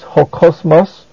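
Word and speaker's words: ho kosmos (ὁ κόσμος): Notice the article ho, matching the word that it modifies in gender, number, and case, ho kosmos. And also notice that the word ho, the article, is a proclitic and is pronounced with the word kosmos, ho kosmos.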